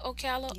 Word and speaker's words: Ocala: And we've heard Ocala. Stories. Oh, that's how you say it, Ocala, yeah.